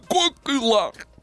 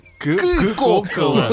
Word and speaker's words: Кока-кола: В 1893-м в патентном бюро Соединенных Штатов зарегистрирована торговая марка, как говорят китайцы, кокила. – Кока-кола. кока а. – да, Кока-кола. Но им трудно произносить э, э, вот эти слова.